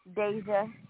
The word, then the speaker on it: Deja: Deja with a D.